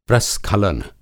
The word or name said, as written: प्रस्खलन